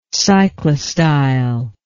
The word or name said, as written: cyclostyle